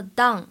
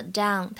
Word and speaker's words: down: 整句话的话呢，发音技巧注意一下，这个 down 千万不要读成汉语当中的 down，哎，发音还是蛮大区别的。首先呢，这个英语当中的 down 它是一个双元音 ow，之后呢跟的是前鼻音，不要发成后鼻音了。